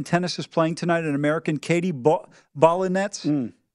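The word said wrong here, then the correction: Volynets: Volley, it's actually Volley Nets, actually.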